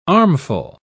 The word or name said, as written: armful